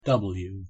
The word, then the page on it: w: English